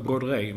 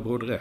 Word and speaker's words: Brodrej: Ja, jag har också trott att det var Brodrej, men det ska tydligen vara Brodrej, säger de.